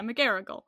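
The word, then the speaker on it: McGarrigle: Yeah, McGarrigal.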